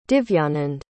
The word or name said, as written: divyanand